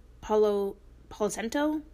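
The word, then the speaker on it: Palo Santo: Uh, Palo Policento, Policenta, Pola Policento.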